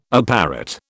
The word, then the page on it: apparat: British English